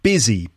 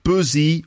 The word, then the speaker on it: busy: Busy, busy, busy. Busy like business. Business, busy. So it's not buzzy or buzzy, despite the U. It's actually busy. It sounds like it's got an I in it.